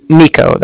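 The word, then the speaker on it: Niko: Nico, that's N-I-K-O. And then the Bank of Cardiff is spelled C-A-R, D as in dog, I-F-F as in Frank, so it's like car and then different.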